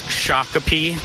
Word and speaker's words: Shakopee: John, it's pronounced Shakopee and YZ.